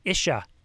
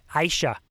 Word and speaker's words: ISHA: And just Friday night, I was presenting to Iowa, the ISHA conference. No, sorry. I said it wrong. ISHA. They had corrected me.